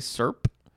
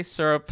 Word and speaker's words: syrup: did you make that one syllable? Did you say SERP? – Yeah. – You say syrup.